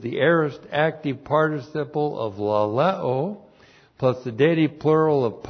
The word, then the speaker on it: laleo: Now, the subject is God, and the verb is laleo. L-A-L-E long O, laleo.